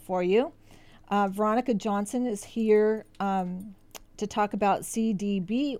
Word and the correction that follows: CBD: oil. The laws are changing with CBD. – All of that. – CBD. – Did I say it wrong? I did.